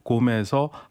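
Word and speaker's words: Qom: 곰. 우리 보통 신문에서는 콤이라고 쓰는데 현지 발음은 곰이라고 합니다. – 예. – 곰에서 한번 또 행렬을 하고요.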